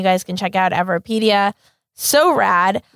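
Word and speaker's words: Everipedia: Everpedia. – Everpedia.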